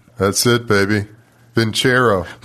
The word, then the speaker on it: Vincero: That's Vincero, V-I-N-C-E-R-O, spelled like Vincero, pronounced like Vincero.